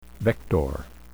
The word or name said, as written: vector